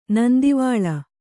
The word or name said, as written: ನಂದಿವಾಳ